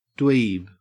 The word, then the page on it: dweeb: Australian English